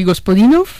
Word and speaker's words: Gospodinov: A ver, Georgi Gospodinov, sí.